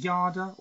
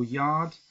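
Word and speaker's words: Yarde: Is it Yarda or Yard? How do you pronounce Yard.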